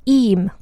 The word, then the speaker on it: Eyam: And so the village, the name of it, it's spelled E-Y-A-M. And as per their own website for this village, it's pronounced Eam, like E-E-M, so I'm hopefully pronouncing it properly.